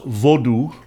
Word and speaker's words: Vodou: Let's begin with Haitian voodoo, and it's often pronounced vodou, vodou, because it's the most well known, I think.